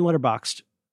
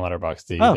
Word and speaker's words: Letterboxd: I could do this on Letterboxd. Which has a silent D. – It's not silent. You just said it at the end of the word. Letterboxd.